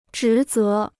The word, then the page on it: 职责: zhí zé